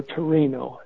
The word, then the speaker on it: Torino: or Torino, as Italians pronounce it.